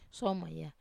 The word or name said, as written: soma